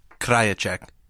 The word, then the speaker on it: Krajicek: Krajicek.